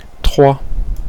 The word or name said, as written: trois